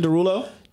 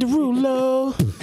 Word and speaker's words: Derulo: Jason Derulo. – Jason, you ain't say his name right. You got to sing. – Jason who? – Jason Derulo.